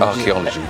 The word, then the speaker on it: Arkeology: And Arcology is the name of the album. – Archaeology. – Sorry. – Yeah. – Because I was looking... – It's spelled with a K, yeah. – A-R-K-E-O-L-O-G-Y.